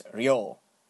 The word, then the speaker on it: Ryo: In this case, it's a ri, R-I, and right next to it would be a small yo, y-o, and this makes the sound ryo. Mm. So, and his name is, and the vowel is elongated by a u vowel right after it, so his name would be pronounced ryo.